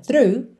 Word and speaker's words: through: Through, lembra que embora tenha muita letra aí, né? muitas letras nessa palavra. É simples a pronúncia through, through.